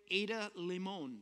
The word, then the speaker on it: Ada Limón: The Hurting Kind, it's the former poet laureate Ada Limón. That's what we decided you pronounce it as.